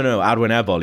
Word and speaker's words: arwynebol: Achwynebol. I went, no, Arwynebol.